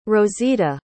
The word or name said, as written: rosita